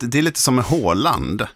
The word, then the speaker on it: Haaland: Fotbollsspelaren, alltså norska, att folk till höger och vänster säger Haaland istället, därför att så uttalar ju engelsmännen det, till exempel när man hör referat och sådär, men 2 A är ju Å.